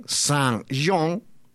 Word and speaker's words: Saint John: Te cuento además de que la mitad de la isla de Saint John, lo digo bien, Saint John, ha sido declarada como parque nacional y alberga un ecosistema rico en fauna y naturaleza.